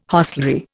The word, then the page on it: hostelry: Indian